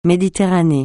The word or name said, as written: Méditerranée